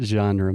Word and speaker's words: genre: The way we pronounce it in English, genre. – Genre. – One more time. – Genre. Genre. – So imagine the name John, but with a j, genre.